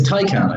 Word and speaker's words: Taycan: So, speaking of battery life, how far does the Taycan go? It's Taycan, I think. I'm pretty sure that's how you pronounce it.